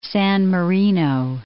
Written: San Marino

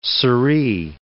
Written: siree

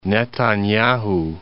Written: Netanyahu

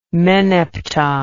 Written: meneptah

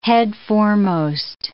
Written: headforemost

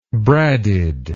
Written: bradded

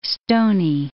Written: stoney

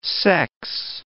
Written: sex

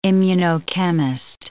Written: immunochemist

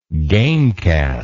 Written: gamecast

